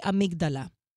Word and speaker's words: amygdala: So it's teaching you that once they flip the lid, right, and they get to, it's almost like, and it's interesting, what, how do you say amygdala? Amygdala? – You said it, yeah.